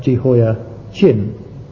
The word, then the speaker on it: Jehoiachin: Now just to make the distinction clear, I'll pronounce that Jehoiachin, ending C-H-I-N. His father ended K-I-M.